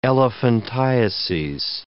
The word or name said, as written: elephantiases